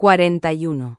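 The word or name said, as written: cuarenta y uno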